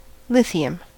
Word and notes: lithium: American English

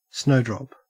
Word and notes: snowdrop: Australian English

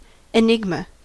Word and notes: enigma: American English

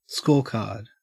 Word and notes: scorecard: Australian English

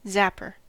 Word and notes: zapper: American English

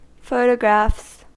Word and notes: photographs: American English

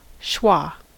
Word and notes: schwa: American English

